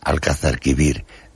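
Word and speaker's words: Alcazarquivir: Se pronuncia bien. Alcázar Quibir, de la provincia de Larache.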